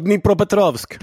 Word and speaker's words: Dnipropetrovsk: Man har spelat i bland annat Midtjylland faktiskt, i Danmark där, men framförallt tillhört då Dimipro. Ledsen för uttalet. – Dnipropetrovsk.